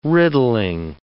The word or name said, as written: riddling